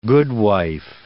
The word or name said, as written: goodwife